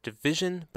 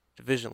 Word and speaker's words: DVSN: It's by an artist called Division, but it's spelled DVSN, in case you want to look it up. This is a track called Claim from the EP Morning After. Division, let's hear some modern grooves.